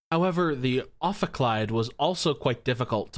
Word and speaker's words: ophicleide: I thought it was Ophicleide. – Ophicleide? Well, let's see if Google has any insights. You're right. It is Ophicleide, according to Wikipedia. – Yay. That's 1 point for Allison in the pronunciation war. – Excellent. Ophicleide it is.